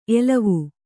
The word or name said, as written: ಎಲುವು